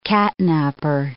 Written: catnaper

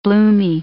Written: bloomy

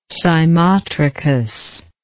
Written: cymotrichous

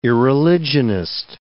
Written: irreligionist

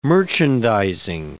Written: merchandising

